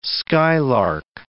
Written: skylark

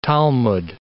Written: Talmud

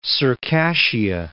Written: Circassia

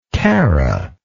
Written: cara

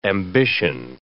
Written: ambition

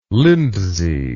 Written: lindsey

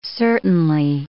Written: certainly

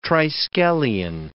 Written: triskelion

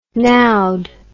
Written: nowed

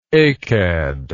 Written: achad

